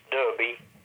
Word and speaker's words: Derby: pronounced Derby, yeah? – Yeah. – Pronounced Derby, spelled Derby, yeah.